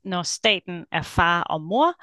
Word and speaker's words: Når staten er far og mor: Uh, so the title of my my dissertation is "Når staten er far og mor: Børneværnets anbringelser øh, af børn i Danmark 1905 to 1975.